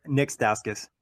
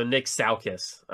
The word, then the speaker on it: Stauskas: Say it one more time for me. – Nick Stauskas. – They have Nick Stalkett. What? That's so difficult. Yeah. So Nick Stauskas.